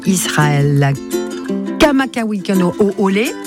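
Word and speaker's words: Israël Kamakawikano Oole (Israel Kamakawiwoʻole): C'est Israël Kamakawikano Oole. – Ah, pas mal, déjà, la prononciation.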